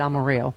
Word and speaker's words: Amarillo: Chip Roy, who is a Republican douchebag and also a putt-putt outside Amarillo. – Right. – Amarillo. – Amarillo. – Yeah. – Yeah. – Uh-huh. – They pronounce the L's in that.